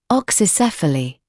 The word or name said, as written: oxycephaly